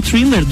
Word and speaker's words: Thriller: O vocalista do Imagine Dragons, Dan Reynolds, revelou que sempre amou o vídeo de thriller. É, tá certo? Eu tô falando certo? – Thriller, é aham.